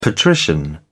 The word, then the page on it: patrician: British English